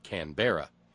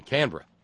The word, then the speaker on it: Canberra: And helpfully provided me a pronouncer so I wouldn't say Canberra in Canberra.